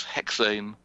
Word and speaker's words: Hexane: Now, another supernova is Hexane 527. I don't know if you've heard of him. It's French. It no doubt should be pronounced Hexan, with 527 in French.